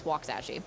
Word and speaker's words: Waxahachie: It's spelled Waxahachie, but it's Waxahachie.